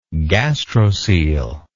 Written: gastrocoel

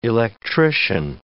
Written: electrician

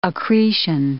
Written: accretion

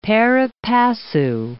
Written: pari passu

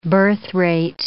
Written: birthrate

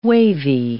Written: wavy

